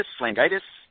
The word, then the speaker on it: Lagunitas: It's Langitas? – Lagunitas? – Is it? It's L-A-N-G-I-T-A-S?